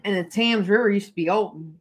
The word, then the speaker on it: Thames: So the Prospect of Whitby was right on the River Thames. Thames. – Silent H.